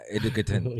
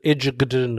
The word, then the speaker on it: Egerton: Yeah, Taron Egerton Is it Edgerton? – I think it's a soft J. – Ah, Edgerton. – Yeah, Edgerton.